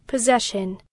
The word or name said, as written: possession